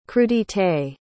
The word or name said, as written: crudités